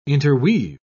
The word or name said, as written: interweave